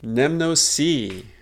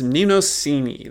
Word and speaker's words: Mnemosyne: In Amnesia, they're explicitly singing about sweet Nemnosi, as they say it, but they must be using speculative ancient Greek pronunciation because I always knew her as Mnemosyne.